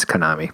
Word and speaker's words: Konami: Is that how you say it? – No, it's Konami, but I say Konami because I'm American.